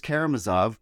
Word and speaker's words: Karamazov: And I say I would pick The Brothers Karamazov, which I'm told is actually the way to pronounce it, because it's long and I haven't read it.